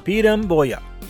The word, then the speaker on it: piramboia: Another name in Portuguese for the lungfish is a piramboia, right? Piramboia, which is spelled P-I-R-A-M-B-O-I-A.